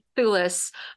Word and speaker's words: Thewlis: How do you say his name? I'm sorry. – Thewlis. – Yeah. David Thewlis.